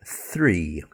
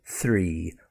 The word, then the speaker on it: three: Try it with the word three, okay? Th. Tongue hits the back of the teeth. Th. Three. Or with the finger in front of your lips, try it.